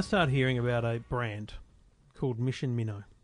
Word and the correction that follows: Winnow: Oh. – Mission Winnow. – Winnow? – Winnow.